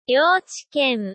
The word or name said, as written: youchiken